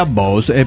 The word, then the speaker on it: Bose: Se pronuncia Bose, pero yo le digo Bose.